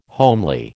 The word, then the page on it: homely: American English